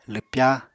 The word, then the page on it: lwpya: apatani